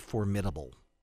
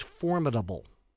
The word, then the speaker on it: formidable: By the way, that word is not formidable. I know that I make pronunciation mistakes from time to time, but I hear so many people say formidable. Oh, yeah, I hear that all the time. It's formidable. You agree, it's formidable.